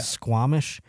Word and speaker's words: Squamish: Squamish, British Columbia. Am I saying that correctly? Yeah. Squamish.